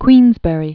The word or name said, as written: Queensberry